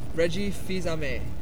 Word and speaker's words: Fils-Aimé: And now I know how to say it for everyone. Reggie Fils-Aimé. That's how you say it.